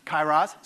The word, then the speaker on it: kairos: To say it properly, you really have to get the guttural kairos.